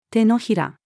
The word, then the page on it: 掌: female